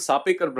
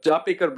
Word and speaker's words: Chapekar: So two brothers called the Sapekar brothers, or the Chapekar brothers, I always difference of pronunciation, but Chapekar brothers decided that they were going to kill him.